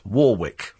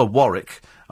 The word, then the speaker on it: Warwick: It's Dionne Warwick. It's pronounced Warwick. That's how she pronounces it. And to call her Warwick either shows great ignorance or just rudeness, I'm afraid.